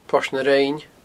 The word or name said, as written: Port na Rinne